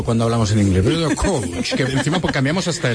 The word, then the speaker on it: coach: Bien pronunciado como anglicismo es coach. – Coach. Vale, pues. – Y así en español, coach.